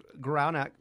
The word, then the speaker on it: Guralnick: Guralnick, yeah.